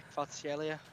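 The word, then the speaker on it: Kvaratskhelia: Then there's Kvitsa Kvartaskhelia, which is a very difficult name to pronounce.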